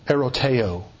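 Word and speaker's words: eroteo (ἐρωτάω): When you go to the Greek, you'll find a word used, a Greek verb that is pronounced eroteo.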